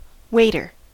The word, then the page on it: waiter: American English